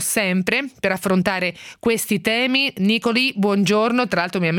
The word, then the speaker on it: Nicolì: Chiedevo alla mia assistente: ma come si pronuncia, che non me lo ricordo, Nicolì o Nicoli? Nicolì, e quindi abbiamo accontentato insomma la pronuncia.